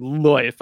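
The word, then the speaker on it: Lyfe: Yes, he pronounces it Loif.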